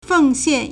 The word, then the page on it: 奉献: fèngxiàn